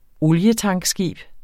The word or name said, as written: olietankskib